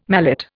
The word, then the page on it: mallet: Indian